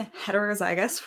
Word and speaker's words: heterozygous: So I am heterozygous for the gluten sensitivity gene.